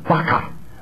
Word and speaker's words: baka: Do you know how you say it in Hebrew? In this particular case, baka, baka, baka, baka, baka, baka, emptied out.